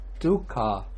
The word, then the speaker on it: dukkha: We say dukkha, long A. Jara, dukkha, long A. Vyadi, dukkha, long A. But maranang dukkhang, here we have the ung sound.